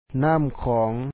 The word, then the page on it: ນ້ຳຂອງ: nàm khɔ̌ɔŋ